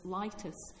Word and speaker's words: Litis: So for instance, you have a phrase like Dominus Litis, L-I-T-I-S. It's no Dominus Litis that lawyers were or are saying. It's Dominus Litis that's gone through the great vowel shift.